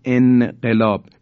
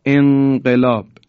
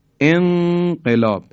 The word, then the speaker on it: انقلاب: یا در انقلاب، نون در قاف، کسی نمیگه انقلاب، میگیم انقلاب، انقلاب.